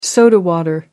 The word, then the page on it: soda water: British English